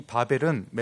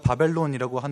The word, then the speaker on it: 바벨: 우리가 잘 알다시피 이 바벨은 메소포타미아 지역에 있는 그 바벨을 의미하는데요. 훗날 바벨론으로 불리는 국가의 이름이 여기에서 유래합니다. 우리가 바벨론이라고 하는 이 발음은 원래 그리스식 발음이고요. 히브리어와 중동식 바벨론 그 발음으로는 원래 바벨 똑같습니다.